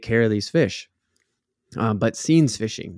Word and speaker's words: seines: I'm gonna blow the pronunciation because it's S-E-I-N-E-S. It's scenes. Yes, that's how you say it. Scenes. S-E-I-N-E-S.